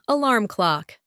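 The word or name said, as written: alarm clock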